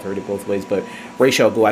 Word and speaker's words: Ra's al Ghul: Because I've always heard In the comics and the animated series, they pronounce Ra's al Ghul. And I think even in Arabic, that where that name comes from, that's how it's pronounced.